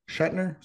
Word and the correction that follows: Suchanek: is that how you say his name? Anyway, he broke the record. – I'm going to pronounce it for you here in a moment. Suchanek, that's it.